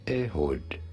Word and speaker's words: Ehud: How do you go about pronouncing it? Ehud. Ehud.